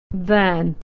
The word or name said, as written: than